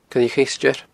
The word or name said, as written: coilleacheisteir